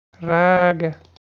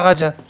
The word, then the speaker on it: raga: Comment, ça se prononce Raga Raga. Attends. C'est pas Raja, c'est Raga. Raga.